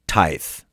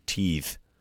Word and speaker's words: Tithe: I think I was mispronouncing it. I was saying teeth, but yeah, that's definitely not how you're supposed to pronounce it.